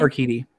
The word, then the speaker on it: Urquidy: Urquidy.